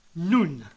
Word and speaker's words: nun: Next, there is the mystery of the missing nun, pronounced noon rather than nun.